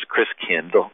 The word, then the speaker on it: Christkind: And the German pronunciation of Christ child is Christkind, like kindergarten and kindercare.